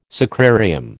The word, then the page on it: sacrarium: American English